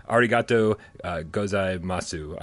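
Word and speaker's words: Arigato gozaimasu: Arigato uh, gozai masu.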